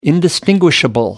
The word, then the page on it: indistinguishable: American English